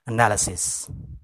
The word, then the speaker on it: analysis: Ya, sekali lagi, pengucapan yang benar adalah analysis.